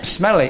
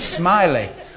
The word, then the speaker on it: Smellie: And our music teacher was called Mr. S-M-E-L-L-I-E. How do you pronounce that? Well, I said Mr. Stinker, but it was Mr. Smelly. He pronounced it Smiley.